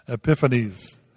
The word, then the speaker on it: Epiphanes: Now, Judea was under the rule of, the name is pronounced many different ways, Antiochus Epiphanes, Epiphanes. That's it.